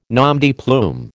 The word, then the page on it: nom de plume: American English